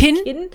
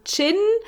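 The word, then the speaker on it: Qin: Aber das U ist nicht da. Ich habe nachrecherchiert, eigentlich ist es Chinesisch und heißt eigentlich Chin, aber sie sagen Kin. Also die deutsche Aussprache ist tatsächlich Kin. – Wie mein Kin im Gesicht. – Ja, die englische Aussprache wäre Chin.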